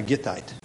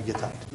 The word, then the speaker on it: Gittite: One of my favorites because his name rhymes with his home city, Ittai the Gittite.